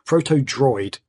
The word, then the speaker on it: Protodroid: So, Protoid? What? – Yes. Oh. Protoid Delta, sorry, is what it's called. – How do you spell that? – I've completely mispronounced that. Protodroid. What did I say? Protodroid.